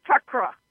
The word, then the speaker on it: chakra: And we're going to go back to something that I believe we know that, but when I said chakra and it's chakra, how do you correctly pronounce the Sanskrit word? That is exactly what I said, correct? – The Sanskrit word is chakra.